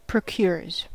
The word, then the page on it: procures: American English